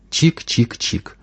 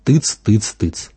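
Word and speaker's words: !!!: Произносить его можно так – чик-чик-чик или тыц-тыц-тыц. Главное – три раза повторить один слог.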